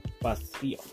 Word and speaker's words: Basile: My like, the name that is going to be, like, legal is Basile. – Basile. – Basile. But it is spelled like Basil.